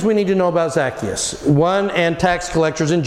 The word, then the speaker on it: Zacchaeus: There's a lot of ways. The actual way to pronounce it is Zacchaeus.